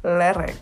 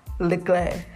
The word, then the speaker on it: Leclerc: I used to say Lerec, but it's like Leclerc. I think in French it's Leclerc. But all I'm saying is I used to pronounce it wrong until someone said no, it's Leclerc.